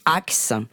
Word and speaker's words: Hacks: C'est l'une des deux héroïnes de la série la plus régalante que j'ai vue depuis un bail, Axe. – créé par Lucia Agnello. – Ça s'écrit H-A-C-K-S.